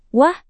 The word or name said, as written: wah